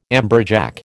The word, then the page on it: amberjack: American English